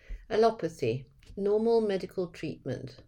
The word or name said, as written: allopathy